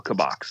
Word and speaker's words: Kabak: But and he kept saying, you know, Kabak. So that's I think you guys are pronouncing it just fine.